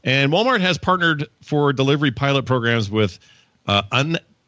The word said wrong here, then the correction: Udelv: Am I saying that right? I think Udelv. – So. Undelve? – Undelve. Like you. – Udelve.